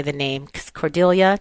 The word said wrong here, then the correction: Cordillera: did I say it right? – The Cordillera.